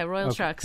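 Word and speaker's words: Royal Trux: Yeah, Royal Trucks.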